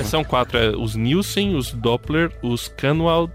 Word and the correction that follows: Kahnwald: o Kahnwald e os Tiedemann. Ah, falei certo ou É, tá- até que foi bem pro seu alemão, viu? – Ah, foi que, que, que legal, que legal.